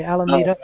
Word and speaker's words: Alameda: I'm not sure how to pronounce it. – Alameda. – It looks like an M-A, but okay, Alameda. – Alameda, yeah. – Alameda is underneath it, but above it. – Yeah, the L is silent.